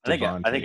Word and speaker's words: Devon: So I see that name and I think Devin, and I was saying it wrong for the first couple of weeks. It is Devon.